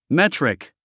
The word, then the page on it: metric: métrik; メトリック